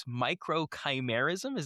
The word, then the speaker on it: microchimerism: How exactly do we pronounce microchimerism? Is that correct? – Microchimerism. So you were okay. – Really, really close.